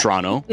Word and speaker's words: Toronto: Toronto.